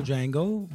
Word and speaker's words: Django: The original Django, the guy who's like I know it's the D is silent.